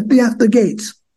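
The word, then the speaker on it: Theaster Gates: You're Theaxter Gates, right? – By the way, where's that? – Theaxter Gates. Am I saying his name right? Yeah, Theaxter Gates.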